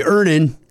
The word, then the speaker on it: Earnin: Earnin. – Earnin. – E-A-R-N-I-N is pronounced Earnin. I don't know how else you would pronounce it, but they really want to be clear that that's how to pronounce it.